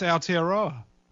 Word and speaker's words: Aotearoa: It's pronounced Aotearoa. – Aotearoa.